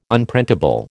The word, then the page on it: Unprintable: American English